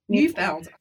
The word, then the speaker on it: Neufeld: New Orleans After the Deluge, and it's by Josh Neufeld. Is that how you say his name? – Neufeld.